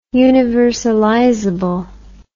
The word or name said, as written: universalizable